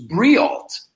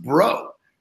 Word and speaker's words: Breault: And his last name is spelled B-R-E-A-U-L-T. And I always thought I know him 10 years, I thought his name was Briault, Scott Briault. Well, he comes on my podcast and he says his name is Bro. It's pronounced Bro.